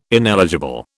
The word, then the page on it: ineligible: British English